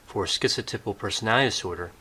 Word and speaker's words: schizotypal: Today I'll be talking about schizotypal personality. – Schizotypal. So I think he has the T in there.